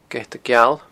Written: geatageal

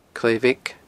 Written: Clach Bheag

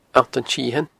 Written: Allt an t-Sithein

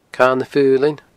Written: Cadha na Faoilinn

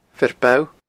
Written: Fear Bogha

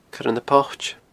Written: Coire na Poite

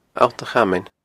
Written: Allt a Chamain

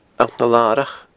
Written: Allt na Larach

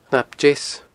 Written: Cnap Deas

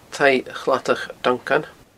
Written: taigh chladach duncan

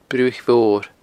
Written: Bruthaich Mhor

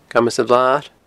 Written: Camas a' Bhlair

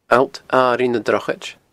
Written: Allt Airigh na Drochaid

